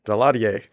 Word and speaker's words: Daladier: And so they were praying, and Hitler, and then the British Prime Minister, Neville Chamberlain, and the French Prime Minister, I had to look up his name to pronounce it. It's Daladier. I still don't know if I'm saying it right, but Dad's shaking his head, yes. Daladier.